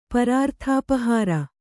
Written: ಪರಾರ್ಥಾಪಹಾರ

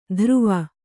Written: ಧ್ರುವ

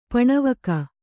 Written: ಪಣವಕ

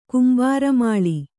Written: ಕುಂಬಾರಮಾಳಿ

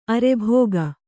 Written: ಅರ್ಭಿಕ್ಕು

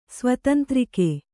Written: ಸ್ವತಂತ್ರಿಕೆ

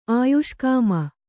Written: ಆಯುಷ್ಕಾಮ

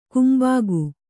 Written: ಕಂಬಿಗ

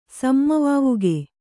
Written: ಸಮ್ಮವಾವುಗೆ

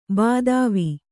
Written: ಭೂಧವ

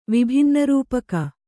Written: ವಿಭಿನ್ನರೂಪಕ

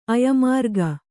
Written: ಅಯಮಾರ್ಗ

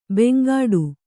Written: ಬಾಂಗಡಿ

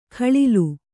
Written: ಘಳಿಲು

